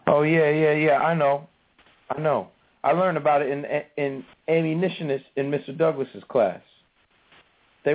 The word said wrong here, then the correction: abolitionist: Only it's pronounced abolitionist.